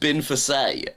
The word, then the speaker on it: Binface: Binface is actually pronounced Binfasay.